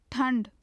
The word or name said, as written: thand